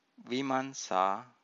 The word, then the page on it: vimansa: Pali